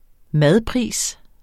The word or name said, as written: madpris